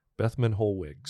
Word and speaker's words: Theobald von Bethmann Hollweg: You say his name. – This is Theobald von Bethmann Hollweg. – That's right. Bethmann Hollweg.